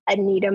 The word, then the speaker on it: Anita: I am at I am Anita Monique. So my name is spelled A-N-I-T-A. Monique is M-O-N-I-Q-U-E.